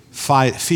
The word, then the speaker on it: fides: from an old French word or the old Latin word fides is the way they pronounce it.